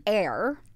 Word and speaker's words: Aire: Air. And she did confirm that's how you pronounce it. – A-I-R-E.